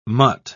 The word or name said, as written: mutt